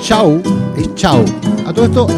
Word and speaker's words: Shaw: Pero él era peruano y su apellido es inglés. Chau, es Chau. S-H-A-W. Raúl Chau Moreno. La W o la W se pronuncia como U. Chau.